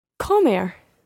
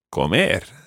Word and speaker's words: comer: Comer. – Comer. – Comer. – Okay, when you're saying an infinitive, always trying to put the pronunciation, or the stress rather, on the last syllable.